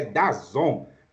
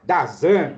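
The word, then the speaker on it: DAZN: É, pela primeira vez eu ouvi a pronúncia correta, é Dazon. Né? Então vamos falar Dazan, Dazin, é, Dazon.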